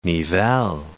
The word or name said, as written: Nivelles